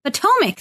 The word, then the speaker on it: Potomac: Potomac? Am I saying that correctly? I don't even know. – Potomac.